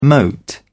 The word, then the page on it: mote: British English